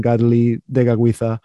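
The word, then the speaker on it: Kateri Tekakwitha: But you use the Mohawk pronunciation, Gadeli Degagwitha.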